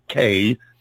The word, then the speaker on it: Cay: I think you're going to be hearing a lot more from Walkers K, that's C-A-Y, which is sometimes pronounced Kai, but we won't get into that right now.